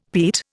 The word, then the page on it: peat: Indian